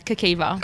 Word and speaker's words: Kikiva: You own a gym brand, I guess, like clothing brand called Kikiva. – Yeah. – Am I saying that right? – Yeah, you are. Well done. Everyone always is like, at the start, but yeah, Kikiva.